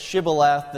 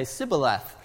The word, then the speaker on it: Shibboleth: Because of their language difference, the Gileadites had taken the crossing of the ford at the Jordan, and whenever someone would come, they'd say Shibboleth. And if they couldn't pronounce it, Shibboleth, the men of Ephraim would say Sibboleth, and because they couldn't pronounce the SH.